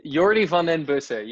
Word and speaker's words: Jordi Van Den Bussche: Did I pronounce that right? – Jordi Van Den Bussche. You almost had it.